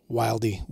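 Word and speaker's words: Wylde: And to answer that easily, there's a chambering called 223 Wild. – Yeah. – Mm-hmm. W-Y-L-D-E. I've also heard it pronounced Wildy, Wildy, and others.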